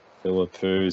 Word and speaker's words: Philippou: And Mateus Philippou, is that how you say it? Ari, Tori? Is that how you say it? Is that close enough. – Close enough? Okay.